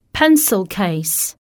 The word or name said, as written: pencil case